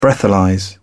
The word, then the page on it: breathalyse: British English